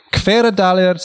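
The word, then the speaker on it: Hveradalir: Kveradalir, South Iceland. Did I pronounce that right? That was excellent.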